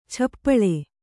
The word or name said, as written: ಛಪ್ಪಳೆ